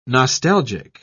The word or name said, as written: nostalgic